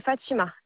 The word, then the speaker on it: Fatima: Le prénom, c'est Fatima, F-A-T-I-M-A.